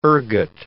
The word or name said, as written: ergot